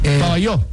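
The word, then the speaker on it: Pavajeau: En el Armando Maestre de Pabajau. – Pabayó. – Es Pabayó. – Sí, se pronuncia Pabayó.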